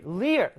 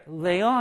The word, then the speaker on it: rear: 자, 우리 leer, 레어 아니고요. leer이라고 발음하는데요.